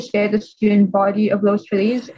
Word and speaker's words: Los Feliz: So the first one I read was, so it was called The Mysterious Haunting of Los Feliz.